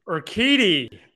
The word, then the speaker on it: Urquidy: 10-10 with a 3.75, kind of similar to Mr. Urquidy, how did I say it again? – Urquidy. Jeez, it's no wonder we got all those views.